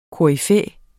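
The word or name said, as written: KORYFÆERNES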